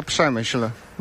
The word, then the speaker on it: Przemyśl: Bueno, se llama Przemyśl.